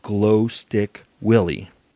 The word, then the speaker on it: Glostik Willy: And I came across a band called Glow Stick Willie. I will spell that for you. It's G-L-O-S-T-I-K. And then Willie is W-I-L-L-Y.